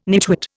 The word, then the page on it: nitwit: Indian